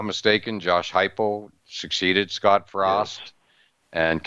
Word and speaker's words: Heupel: Now I'm noticing I see Josh Heupel, I hope I'm pronouncing that correctly, Oklahoma quarterback. – Yeah, Heupel. Pronounced it Heupel.